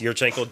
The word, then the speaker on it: Yurchenko: I don't want to say it wrong. Yurchenko? – Yeah. – Yes, Yurchenko, double pike.